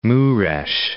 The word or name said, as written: Mures